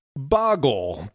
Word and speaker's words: Bahgol: Last time, everyone objected to my pronunciation, but the word is something like Bahgol, B-A-H-G-O-L, B-A-H-G-O-L.